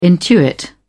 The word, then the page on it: intuit: British English